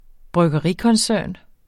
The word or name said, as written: bryggerikoncern